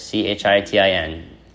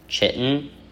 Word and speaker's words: chitin: It's C H I where is it C H I T I N chitin chitin and its effects on inflammatory and immune responses.